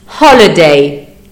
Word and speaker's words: holiday: Attention, le H en anglais n'est pas muet, il est soufflé. Mettez votre main devant votre bouche, si vous sentez de l'air, vous prononcez bien le H. – Holiday, happy.